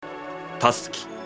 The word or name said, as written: Tasuki